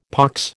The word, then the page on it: pox: American English